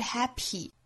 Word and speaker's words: happy: 首先第一个要注意的呢，就是 happy 这个词，我们注意后面的这个字母 p 对应的这个 p 的发音，在这样一个单词里呢，我们听到更多的情况其实不是发成十足的 p 的发音了，不是 happy，而我们听到更多呢是类似于一个 b 的发音 happy happy，这就涉及到一个爆破音在单词内部元音之间的一个送气打折的情况。